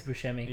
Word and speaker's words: Buscemi: I think it's Buscemi.